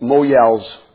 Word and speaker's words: mohel: They're called Moyels. It's spelled, I think, M-O-H-E-L, but it's pronounced Moyel.